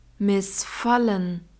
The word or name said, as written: missfallen